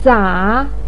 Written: sa3

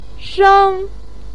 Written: sheng4

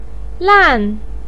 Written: lan4

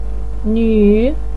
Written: nuu3

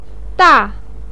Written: da4

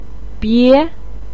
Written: bie2

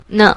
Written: ne

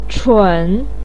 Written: chun3

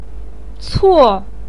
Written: cuo4

